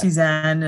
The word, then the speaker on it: Tsu Shan: I think when we first spoke, it's pronounced Suzanne.